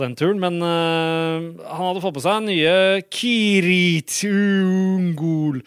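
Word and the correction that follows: Cirith Ungol: eller Sirith Ungol, som de uttaler det selv. Sirith Ungol uh, eh, starta allerede i 71 i California.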